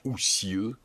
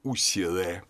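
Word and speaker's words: Osiris: Это слово правильно произносить «усир». Копты его произносили «усире». В позднем египетском языке это конечная огласовка была «усире».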